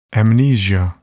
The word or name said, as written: amnesia